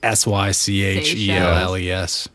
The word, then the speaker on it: Seychelles: How do you pronounce it? Seychelles? – It's like Seychelles.